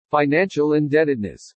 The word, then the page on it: FINANCIAL INDEBTEDNESS: English